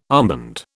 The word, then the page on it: armband: British English